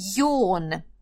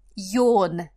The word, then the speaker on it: yawn: Number seven – yawn. Йон, зевать, прекрасный глагольчик, пишется Y-A-W-N, и мне кажется, даже похож на тот звук, который мы произносим, когда с вами зеваем, йон.